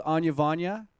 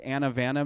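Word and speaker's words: Anavana: Their website is, I'm going to spell it because they told me to pronounce it one way and spelling you would never guess. It's A-N-A-V-A-N-A .com. They're out of New York. Again, that's, you would phonetically say that Anna Vanna maybe? – I would call it Anna Vanna.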